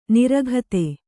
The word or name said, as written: ನೀರೋಗತೆ